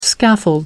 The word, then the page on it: scaffold: American English